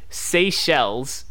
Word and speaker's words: Seychelles: And yes, you really got the pronunciation wrong of his hometown. It's pronounced Seychelles, or maybe not his hometown, but where he currently resides. It's pronounced Seychelles, and it is not in Australia.